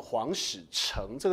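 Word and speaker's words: 皇史宬: 本来书被拿去北京抄，抄完之后又用皇家的力量送回南京，然后副本就放在北京的皇史城，这个字念作城，城市的城。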